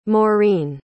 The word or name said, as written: Maureen